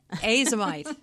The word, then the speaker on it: Azomite: I think azomite sounds prettier than the hard A, the long A.